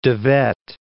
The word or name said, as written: Wet, de